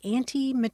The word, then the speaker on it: antimetabole: It's spelled A-N-T-I-M-E-T-A-B-O-L-E, antimetaboly.